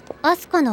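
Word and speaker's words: 飛鳥: アスカちゃんの名前もいいよね。古風というか和的な感じで。 – そうかい。漢字の組み合わせは悪くない。鳥が飛ぶと書いてアスカ。呼び方も熟字君であるところがいい。アスカの語源については。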